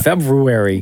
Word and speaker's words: February: February. – No, you don't need to say the R. Just go February.